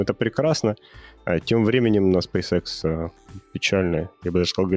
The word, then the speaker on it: SpaceX: Что у тебя произношение компании, в России все говорят SpaceX, а, видимо, в Штатах все говорят SpaceX. Я даже сначала не понял, про какую компанию ты говоришь, так на секунду залип.